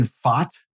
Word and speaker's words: Pfaadt: Brandon Pfaadt, I got his name correctly now, P-F-A-A-D-T, was asked exactly what he's asked to do again.